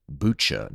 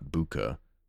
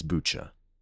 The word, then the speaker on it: Bucha: I've been saying Buka, and as typical, people probably know I pronounce names and places and words that are foreign to me pretty poorly, and I'll probably forget and pronounce it Buka again later, but just so you know, it's Bucha.